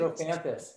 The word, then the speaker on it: Strophanthus: And so keep your heart healthy, keep your frequency alignment, and get some Strophanthus. Did I say it right? – Strophanthus. Yeah, Strophanthus.